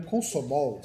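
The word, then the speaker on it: Komsomol: Só que acontece, lá no Comozol, né? É, é no Consomol, sempre erro pronúncia isso aqui que era o, o partido que em russo significa Liga Comunista Leninista da Juventude de Toda a União.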